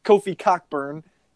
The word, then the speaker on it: Cockburn: Jeremiah Tillman is Kofi Coburn. I'm going to say his name the right way. Kofi Cockburn. He is Kofi Cockburn's bitch.